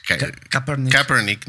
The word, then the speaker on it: Kaepernick: Eh, Kaepernick. ¿no? Eh, Colin Kaepernick,